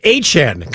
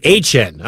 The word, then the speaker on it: Etienne: New way to say ETN. It's Achan, kind of like Achan in Miami, but it's spelled Ettiene. Achan.